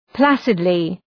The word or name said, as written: placidly